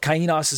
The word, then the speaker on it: kainos: By comparison, the Greek word kainos, some people say kainos or something to that effect, kainos, but kainos is how I pronounce it.